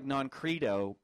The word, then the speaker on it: Noncredo: N-O-N-C-R-E-D-O. Almost like non-credo, but they're... Why can I not talk today? – We don't know. – The correct pronunciation is credo. Non-credo.